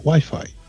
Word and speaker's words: wifi: No se dice wifi, se dice wifi.